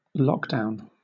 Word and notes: lockdown: British English

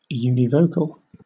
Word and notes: univocal: British English

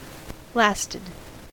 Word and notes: lasted: American English

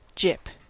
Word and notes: gyp: American English

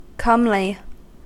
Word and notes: comely: American English

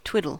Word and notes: twiddle: American English

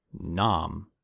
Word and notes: nom: American English

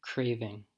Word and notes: craving: American English

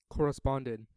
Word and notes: corresponded: American English

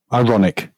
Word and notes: ironic: British English

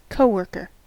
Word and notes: coworker: American English

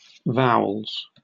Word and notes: vowels: British English

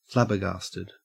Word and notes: flabbergasted: Australian English

